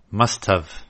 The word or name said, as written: must've